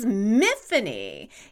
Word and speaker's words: Myfanwy: It's a Welsh name spelled M-Y-F-A-N-W-Y, where the W is silent. So it's like Tiffany with an M. And it's not so hard to say if you take out that silent W.